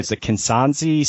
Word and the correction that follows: Kihansi: spray toad. Is that how you pronounce it? Um, Kihansi. Yeah, Kihansi spray toad. That's right yeah,